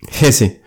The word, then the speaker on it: Hesse: Hesse.